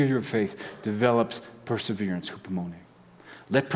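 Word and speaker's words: hupomone (ὑπομονή): Perseverance in Greek is hupomone. Can I have you guys say that with me? Because it's a fun word to say, hupomone, hupomone. Just a reminder, it rhymes with poopomone.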